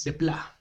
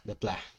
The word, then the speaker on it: Desplat: En la música, ya lo decías, Alexandre Desplat, o como se pronuncia en francés. Desplat. Desplat, muy bien.